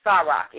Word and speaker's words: Saroc: Her name is spelled SARoC, Sarok.